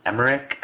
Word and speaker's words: Emmerich: Noah Emmerich, I believe, is how you pronounce his last name.